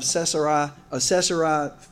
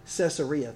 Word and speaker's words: Caesarea: It says, "Jesus went out with his disciples to the villages of Caesarea Philippi." I might have said that wrong. Caesarea, thank you.